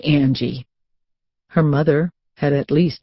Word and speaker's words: Angie: If you want to be short for Angel, it should be Angie, with a long A." – Nicole's lips had twisted into a mean-looking smile. – "To rhyme with mangy."